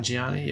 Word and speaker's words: Nanjiani: Nanjiani, yeah.